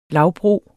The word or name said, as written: lavbro